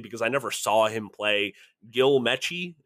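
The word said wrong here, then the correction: Meche: is that how you say it? – Mesh. – See, there you go. I'm dating myself. Gil Mesh.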